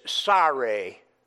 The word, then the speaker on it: Sarai: It's interesting because of the fact that the Hebrew pronunciation of the name found in 16:1 here is Sarai. Like a person who's sorry, kind of like a southern, sorry, if you will.